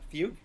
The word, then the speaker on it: Fugue: How do you pronounce that? Fugue? – Yeah.